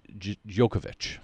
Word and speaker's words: Djokovic: Novak Djokovic.